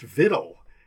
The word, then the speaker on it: victual: But actually, it used to be pronounced vittle. He goes, sir, it's spelled V-I-C-T-U-A-L. It's victual.